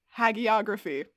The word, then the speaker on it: hagiography: The word of the day today is hagiography. It's spelled H-A-G-I-O-G-R-A-P-H-Y.